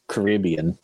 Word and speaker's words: Caribbean: It's pronounced Caribbean. – Caribbean. – No, it's Pirates. It's Caribbean. – I'm not listening to how an American pronounces English words. But Caribbean is the most English of English words.